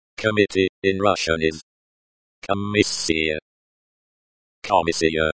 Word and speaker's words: Комиссия: Комиссия note. The stress is on the second syllable.